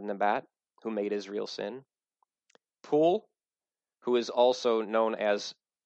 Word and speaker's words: Pul: Pul, king, one syllable.